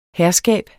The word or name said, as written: herskab